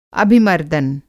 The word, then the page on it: अभिमर्दन: Hindi